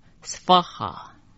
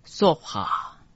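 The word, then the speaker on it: svāhā: 这个娑字只有念 s 这样的音，婆我们前面讲过念法，诃念哈，合起来念成 svaha。也有人念成索哈。五十三句 Siddhaya。五十四也是 Svaha。五十五 Maha Siddhaya。五十六 Svaha。要注意的是，虽然有很多句 Svaha，但是意义并不一样。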